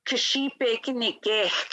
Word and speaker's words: kishipekinikek: So I would say KI/SHEE/PAY/KINI/KAYH. So you pronounce that KISHIPEKINIKEK, which means doing laundry.